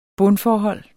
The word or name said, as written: bundforhold